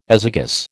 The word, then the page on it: azygous: American English